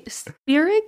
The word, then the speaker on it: Spierig: How would you say that? Spierig? – Spierig, yeah. I would go with that.